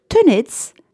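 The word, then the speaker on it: tenez: Avant de lancer la balle, on prononce le mot « tenets », impératif du verbe « tenir », parce que le Z final se prononce encore à l'époque.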